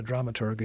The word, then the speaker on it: dramaturg: What a dramaturg is. And all. Have I even pronounced that correctly? – Yeah, that's good. – Okay. – Hard G.